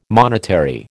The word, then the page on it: monetary: American English